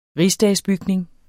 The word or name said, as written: rigsdagsbygning